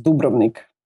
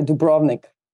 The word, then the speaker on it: Dubrovnik: And then I had to explain, and I used only one word to explain my country, which was Dubrovnik, or how you say it in America, Dubrovnik.